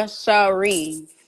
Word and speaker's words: Sheree: It's Tay underscore Sheree, and that's how you find me because I guess somebody else got my have my name.